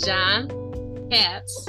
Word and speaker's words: Katz: His name is Jon Katz.